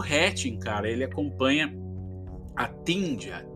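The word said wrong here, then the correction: Tinja: Tin, Tin, se pronuncia Tinia, né? Um nome finlandês, uma pré-adolescente tímida, solitária, que vive com a família ali em uma comunidade de aparências de uma aparência idêntica, né?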